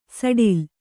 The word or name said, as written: ಸಡಿಲ